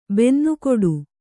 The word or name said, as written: ಬನ್ನಗೆಡು